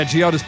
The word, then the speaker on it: Geodis: Yeah, Geodis Park.